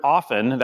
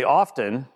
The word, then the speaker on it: often: So I don't know if you were taught, but when you say the word often, that's the way it's supposed to be said, at least that was correct. Now, if you say often, you used to get corrected for that. The T's supposed to be silent? It's okay. People have said it wrong long enough. Now you can say often and it's correct.